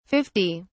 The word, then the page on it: 50: British English